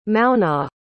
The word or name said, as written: Maonaigh